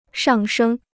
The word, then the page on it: 上升: shàng shēng